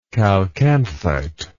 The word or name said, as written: chalcanthite